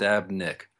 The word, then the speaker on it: Sztabnik: You know, it's funny because it's a Polish last name and there's a Z right there. So yeah. If the Z is silent, you're fine. So it's just Sztabnik.